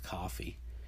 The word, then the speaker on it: Ko-fi: You know, for my blog, I've got a Ko-fi account, or I believe it's actually pronounced coffee,